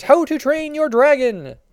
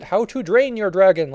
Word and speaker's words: How to Train Your Dragon: How to Train Your Dragon. Do not pronounce it How to Drain Your Dragon like I always seem to do.